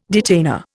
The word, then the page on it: detainer: Indian